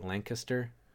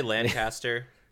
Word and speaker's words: Lancaster: And I know I'm pronouncing it wrong for people that live there, but I think they say like Lancaster. – Yeah, I say Lancaster.